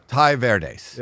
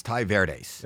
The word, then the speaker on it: Tai Verdes: Ty Verdes. – Yeah. – Yeah, I was saying Ty Verdes, but yeah. It's Ty Verdes.